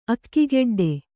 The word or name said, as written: ಅಕ್ಕಿಗೆಡ್ಡೆ